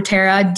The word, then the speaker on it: Tera: You can find me on Instagram at Dr. Tara, D-R-T-E-R-A. – For those listening, Tara is spelled T-E-R-A.